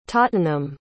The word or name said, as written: tottenham